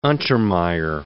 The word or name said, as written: Untermeyer